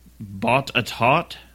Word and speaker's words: Botetourt: It's pronounced Botetourt. – Botetourt. – Botetourt. – That does not look like how it's spelled.